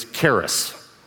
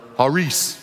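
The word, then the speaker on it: haris (χάρις): Our English pronunciation of it is charis. And put the word up so you can see how it's spelled. And it's fine to say that, but that's really not how it's pronounced. The C in Greek is silent. And so this word is actually pronounced haris. Haris. The I would be like a long E.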